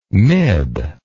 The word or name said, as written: mib